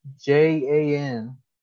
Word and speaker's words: Jan: His name is spelled Jan, J A N, but uh, it's it's pronounced Jan, which is crazy, because there's also a a title holder named Petr Yan, which is actually Y A N. So that, yeah. that that name itself just gets confused from time to time. But Jan Blachowicz, I believe the CH is silent.